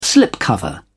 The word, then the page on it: slipcover: British English